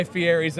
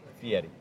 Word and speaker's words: Fieri: Guy Fieri's American Bar and Grill. – By the way, you have to pronounce, if you're going to be on Food Network, Fieri.